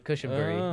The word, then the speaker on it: Cushenberry: I actually think I was saying that wrong. It's more of a U, like Lloyd Cushenberry. – Lloyd Cushenberry. – Lloyd Cushenberry. – So you bury it.